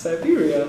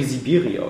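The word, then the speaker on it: Syberia: Siberia. Ja, vollkommen falsch ausgesprochen. – Klang wie Sibiria oder so.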